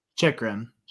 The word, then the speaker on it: Chychrun: And then at that very almost towards the end of the last sort of like six ish minutes in the period, um, Chichurin. Is that how we're pronouncing his last name? Uh, Chichurin? – Jakob Chychrun. – Chychrun. Thank you.